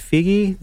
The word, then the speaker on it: Feige: I don't know how to pronounce his name. Is it Feige, the main producer behind all this, all the Marvel content? – I think that's how you pronounce it.